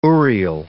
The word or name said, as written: urial